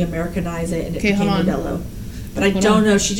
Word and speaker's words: Modelo: Like, Modelo is Modelo.